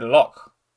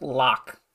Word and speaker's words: loch: Accented H is pronounced as a strongly aspirated H, like the ch, or like the ch in the Scottish word loch. Not pronounced lock.